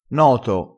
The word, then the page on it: noto: dialettale